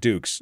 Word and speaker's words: Dukes: My last name is Dukes, D-U-K-E-S.